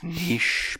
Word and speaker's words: niche: It's niche, people, niche. – Niche like quiche.